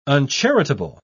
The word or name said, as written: uncharitable